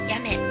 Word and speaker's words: 八女: そして福岡県八女市九州テレコム振興センターとの共同により、コミュニティ FM を中心とした地域活性化プロジェクトが始まりました。そのプロジェクトの一環として実験的に放送していくのが八女グリーンティーカフェです。漢数字の 8 と女と書いて八女、日本有数の高級茶の産地です。